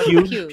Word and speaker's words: Pugh: That's like no, Pugh. Pugh. – Yeah. – You say her name like Pugh, Pugh, Pugh.